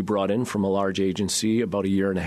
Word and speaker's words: AGENZ: So I mentioned in the intro your new agency, A-G-E-N-Z, pronounced agency, as your in-house creative agency.